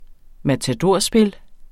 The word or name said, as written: matadorspil